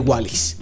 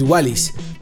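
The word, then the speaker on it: Wallice: La siguiente canción se llama Best Friend y es de Wallis. Wallis, que se escribe como Wally, W-A-L-L-I latina C-E. O sea, como una mezcla entre Wally y Alice. Es Wallis.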